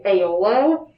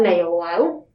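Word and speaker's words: Eolo: Like, one of the main characters is named Aeolo. And I was shocked looking at the book today to discover that that is spelled E-O-L-O. I would not have gotten Aeolo But, like, is that what the author intended?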